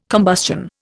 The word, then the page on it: combustion: Indian